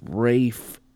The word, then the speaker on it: Ralph: Okay, the BAFTA winning actor's name is actually pronounced Rafe Fiennes. So that's what it is. There's a F at the end of it. Rafe. People say Rafe or Ray Fiennes, but I think it just kind of blends into his last name, you know what I mean? So right. – It's pronounced Rafe, the first name.